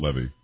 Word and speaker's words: Levy: By the way, I think it's Levy. I think Eugene says it Levy.